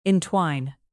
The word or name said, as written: entwine